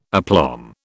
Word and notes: aplomb: British English

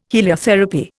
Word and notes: heliotherapy: Indian

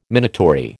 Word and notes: minatory: American English

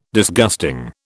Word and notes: Disgusting: British English

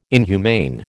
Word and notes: inhumane: American English

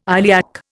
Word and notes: iliac: Indian

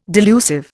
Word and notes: delusive: Indian

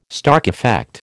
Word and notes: Stark effect: American English

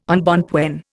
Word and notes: embonpoint: Indian

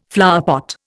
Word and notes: flowerpot: Indian

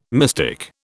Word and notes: mystic: British English

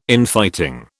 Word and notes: Infighting: British English

